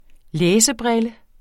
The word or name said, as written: læsebrille